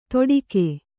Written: ತೊಡಿಕೆ

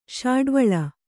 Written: ಶಾಡ್ವಳ

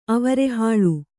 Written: ಅವರೆಹಾಳು

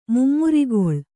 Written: ಮುಮ್ಮುರಿಗೊಳ್

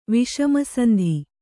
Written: ವಿಷಮಸಂಧಿ

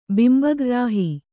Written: ಬಿಂಬಗ್ರ್ರಾಹಿ

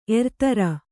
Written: ಏರುತೆರೆ

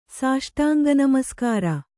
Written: ಸಾಷ್ಟಾಂಗನಮಸ್ಕಾರ

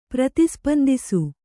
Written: ಪ್ರತಿಸ್ಪಂದಿಸು

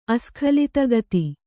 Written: ಅಸ್ಖಲಿತಗತಿ

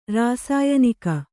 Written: ರಾಸಾಯನಿಕ

